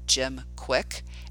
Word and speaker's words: Kwik: And then Quick Brain with Jim Quick, and Quick is spelled K-W-I-K, which is how he spells his last name.